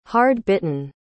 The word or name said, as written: hard-bitten